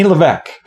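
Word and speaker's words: Lévesque: Rene Levesque. You don't pronounce the S.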